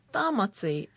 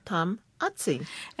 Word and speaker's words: Tamati: Whose surname can easily be mistaken for being Māori, where the pronunciation is Tamati, and in English means Thomas, but in Samoan is Tamati.